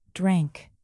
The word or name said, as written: drank